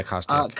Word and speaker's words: Kostyuk: And we saw another one of the favorites to win, Elena Svitolina, stop another Ukrainian, which was in Kostyuk, I believe is how you pronounce it. – Yeah, Kostyuk.